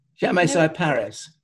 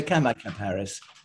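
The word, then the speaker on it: Chamaecyparis: It's the creepy one, and I want to say it's Camoparis. – Chamaesiparis. – Yes, thank you. – Yeah. You can, of course, be very posh and say Camoparis with a silent H.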